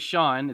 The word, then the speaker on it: Sean: It's actually Sean. It's the Irish spelling, so it throws people off. And then the last name is pronounced Bubeck.